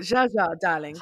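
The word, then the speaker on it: Zsa Zsa: Say her name properly. – Zsa. – That's it. Zsa, darling. She would tell you herself. It's Zsa, darling.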